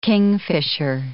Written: kingfisher

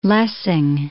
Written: Lessing

